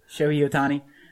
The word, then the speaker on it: Ohtani: Japanese prospect, Shohei Ohtani.